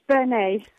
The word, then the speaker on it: Burnet: Yes, it's Bernay, because it's because I'm married to a Frenchman. – Ah. – So we don't pronounce the T.